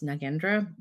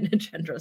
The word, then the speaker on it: Nagendra: My name is Cynthia Nagendra. Also, it's pronounced Nagendra, but I have been using both lately. But my name is Cynthia Nagendra slash Nagendra, and I use she, her pronouns.